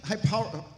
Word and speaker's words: hyperbole: Now, how many think that Jesus is using hyperbole? I can't say that word. – Hyperbole. – Thank you. Hyperbole. I said it. Just putting the accent on the wrong syllable.